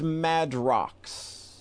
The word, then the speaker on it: Madrox: One guy's named Monoxide and the other guy's name is Madrix. Is that how you fucking pronounce that shit? – I think it's Madrox.